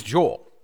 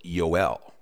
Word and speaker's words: Yoel: So it's Yoel. And Yoel puts the emphasis on the last part of the name, E-L, which in Hebrew is a reference to Elohim, which means God.